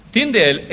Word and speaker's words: Tyndale: Se escribe T-Y-N-D-A-L-E, Tyndale.